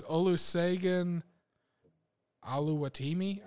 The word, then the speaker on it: Olusegun Oluwatimi: It's it's Olusegun Oluwatimi. I think is how you pronounce his name.